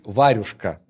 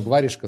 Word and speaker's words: варежка: Объясни, пожалуйста, как варежка, слово пишется варежка. Через букву Е. – Ю. – Варежка. Не варюшка.